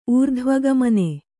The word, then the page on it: ಊರ್ಧ್ವಗಮನೆ: ūrdhvagamane